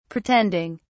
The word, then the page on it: pretending: British English